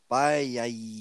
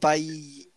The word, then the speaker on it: Baia: Right, okay, so my pick today is a man called Vitor Baia. Vitor Baia. Baia. – Spell it. – B-A-I-A. – Baia. – Baia. – Baia. – Baia, okay.